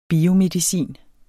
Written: biomedicin

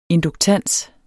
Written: induktans

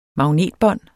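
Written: MAGNETBÅND

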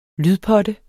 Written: lydpotte

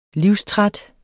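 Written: livstræt

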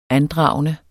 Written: ANDRAGENDERNE

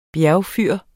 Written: bjergfyr

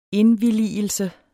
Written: indvilligelse

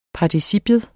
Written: participium

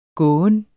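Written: gåen

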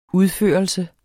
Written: udførelse

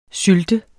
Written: sylt

